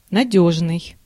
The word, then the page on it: sound: /saʊnd/